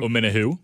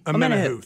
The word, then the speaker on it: Omenihu: Help me pronounce this guy's last name. – Omenihu. – Omenihu, thank you.